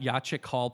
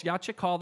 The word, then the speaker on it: Yachik Hall: You got Yachik Hall pronounced properly. That's also a. a I've been practicing. so great job. But again, folks, Yachik Hall, that's the, uh, the Labor Council there on Hawley Road.